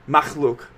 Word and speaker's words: Makhlvk: Makhlvk, right? – Perfect. Perfect. – Sick. Yeah. All right. So Makhlvk, which if I'm freaking randiness, he said it means creatures in Indonesian, right? – Yep.